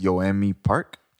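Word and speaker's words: Yeonmi Park: Okay, so I've been following, I think the correct way to pronounce her name is Yoanmi Park.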